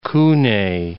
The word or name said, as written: kune